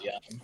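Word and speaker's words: Yeun: Stephen Ewan, obviously great. Or Stephen Yun. I don't remember how to say his name. – I think it's Yun.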